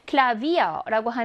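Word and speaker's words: Klavier: Klavier라고 하는 저 단어는 V가 있어요. 하지만 V가 모와 모 사이에 위치하나요? 모음과 모음 사이에 위치해요. 아 하고 복모음 이의 사이에 위치하거든요. 그럼 어떻게 발음한다고요? V 발음을 내요.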